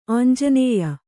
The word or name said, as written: ಆಂಜನೇಯ